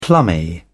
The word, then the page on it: plummy: British English